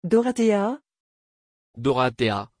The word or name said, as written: Dorathea